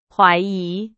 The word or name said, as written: 怀疑